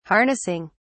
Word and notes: harnessing: British English

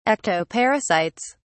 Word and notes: ectoparasites: British English

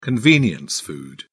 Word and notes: convenience food: British English

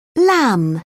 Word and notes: lam: British English

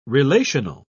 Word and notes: relational: British English

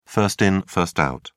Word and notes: first in first out: British English

